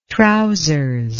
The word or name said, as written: trousers